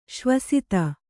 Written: ಸ್ವಾಸ್ತಿ

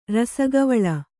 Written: ರಸಗವಳ

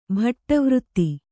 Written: ಭಟ್ಟವøತ್ತಿ

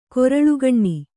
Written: ಕೊರಳುಗಣ್ಣಿ

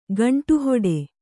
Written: ಗಂಟುಹೊಡೆ

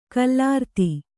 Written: ಗೋಲಾರ್ಧ

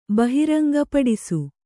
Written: ಬಹಿರಂಗಪಡಿಸು

